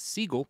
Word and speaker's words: Siegel: Steven Seagal's last name was originally pronounced Siegel, not Seagal.